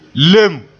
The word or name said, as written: leen